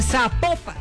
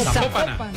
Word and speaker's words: Zapopan: Oiga, nos escriben los oyentes y que no se pronuncia Zapopan, sino que se pronuncia Zapopan. – Zapopan. – Bueno, es Zapopan.